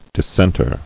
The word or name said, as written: Dissenter